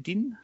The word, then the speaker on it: Dien: Dien? – Yeah.